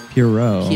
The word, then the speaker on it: Pierrot: Um, so we start the show uh, with kind of some some Barker music and um, and the and the clown, the Pierrot, the, the how do you say? – It? I think it's Pierrot.